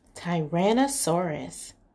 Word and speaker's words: Tyrannosaurus: Tyrannosaurus. This is how you say it. Ty-ran-no-saurus. Tyrannosaurus.